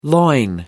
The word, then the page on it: loin: American English